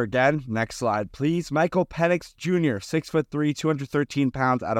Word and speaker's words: Penix: Some people like to pronounce that last name a little bit differently. I'm going to stick with the way it's correctly pronounced in Penix.